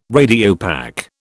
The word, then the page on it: Radiopaque: British English